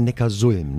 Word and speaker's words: Neckarsulm: Heißt das eigentlich Neckarsulm oder Neckarsulm? Ich glaube Neckarsulm, ne? – Richtig. – Ja, okay, siehst du? Ich habe es tatsächlich lange Zeit falsch gesagt, aber Neckarsulm.